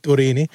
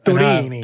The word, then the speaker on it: Turini: Turini. – Že André Turini bude v top 3 nejlepší coach. – Jak Panini, tak Turini.